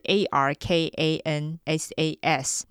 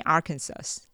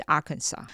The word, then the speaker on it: Arkansas: 那它的拼法是 A R K A N S A S，那它最后一个 S 不发音，所以第一次看到人都会念 Arkansas，可是它其实是 Arkansas。